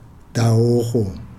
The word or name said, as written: dawoho